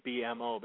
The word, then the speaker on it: BMO: I think people look at it, they think BMO, but it's actually pronounced BMO.